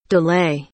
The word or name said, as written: delay